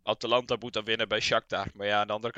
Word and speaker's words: Shakhtar: Ja, dat is, dan moet dus op de laatste dag moet Atalanta winnen bij Shakhtar, zeg ik dat goed?